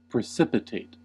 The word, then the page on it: precipitate: American English